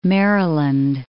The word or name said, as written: Maryland